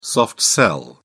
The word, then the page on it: soft sell: British English